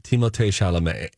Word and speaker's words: Timothée Chalamet: Now, we were also told at one point that we were actually supposed to be pronouncing Timothy Chalamet's name as Timothée Chalamet.